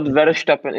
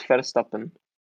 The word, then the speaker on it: Verstappen: It's not Verstappen, it's Verstappen. You're just sad because you can't pronounce the name correctly.